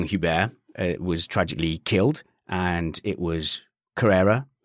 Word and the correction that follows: Correa: I've, I've, said the name wrong, haven't I? – Juan Manuel Correa. – Correa uh, was the driver that ended up crashing into him, I believe.